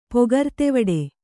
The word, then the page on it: ಪೊಗರ್ತೆವಡೆ: kannada